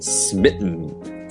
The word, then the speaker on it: smitten: S-M-I-T-T-E-N. – Right, so that sm sound at the beginning might be a little difficult to pronounce, but you can just slowly smitten, put them together. – Smitten, 而且这个 T 就是美国英语里面很多比如 mountain 很多人 mountain 就是变成一个 like almost a D sound.